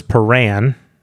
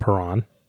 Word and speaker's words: Paran: Lacine, Ganoz Perran, not Perron, Kalam.